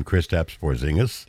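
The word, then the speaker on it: Kristaps Porziņģis: Well, Boston Celtics center, and I'm going to pronounce his name, Kristaps Porzingis. – That's correct. – Hey! – Yeah, it's one of the few European names that's pronounced how it's spelled.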